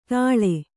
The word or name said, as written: ತಳೆ